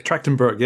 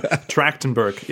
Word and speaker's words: Trachtenberg: Trachtenberg, yeah. I watched an interview with him earlier just to figure out how to say his name. Trachtenberg, yeah, Dan Trachtenberg.